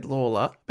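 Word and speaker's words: Lalor: It's actually spelled L-A-L-O-R. I read that you say it, that they said Lawler. And the suburb that we call Layla. – Laylaw is named after him, even though they now say it wrong.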